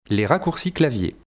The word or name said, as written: les raccourcis clavier